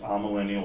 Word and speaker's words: amillennial: Something is pronounced amillennial or amillennial, like asymmetric.